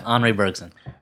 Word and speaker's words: Henri Bergson: Since I work in the film industry, I have been a good example of what Henri Bergson, is that how you say it? – Yeah, Henri Bergson.